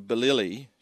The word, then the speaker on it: Billily: How could I know Billily should rhyme with Milly Milly?